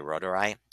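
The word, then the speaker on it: reuteri: And that's that Lactobacilli rotari, using the German pronunciation.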